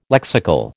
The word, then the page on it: lexical: American English